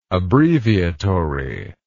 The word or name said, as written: abbreviatory